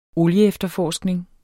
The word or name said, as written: olieefterforskning